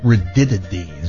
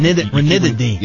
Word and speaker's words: ranitidine: I like that ranitidine. Is that how you pronounce it? – No, there's an N. – Ranitidine. – Yeah, now you got it.